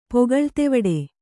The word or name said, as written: ಪೊಗಳ್ತೆವಡೆ